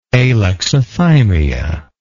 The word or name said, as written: alexithymic